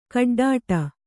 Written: ಗೊಡ್ಡಾಟ

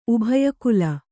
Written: ಉಭಯಕುಲ

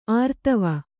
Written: ಆರ್ತವೆ

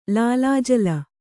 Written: ಲೀಲಾಜಾಲ

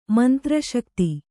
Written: ಮಂತ್ರಶಕ್ತಿ

